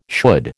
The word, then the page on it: should: American English